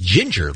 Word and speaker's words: Jinger: I'm being informed by the listeners, one of the Duggan's 20 kids is pronounced Ginger, Lisa. They spell it Jinger because it has to start with a J, but they pronounce it Ginger.